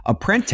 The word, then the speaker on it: Apprente: But now McDonald's buys Apprente. I think that's how you say it. Apprente. – Yeah, sounds right.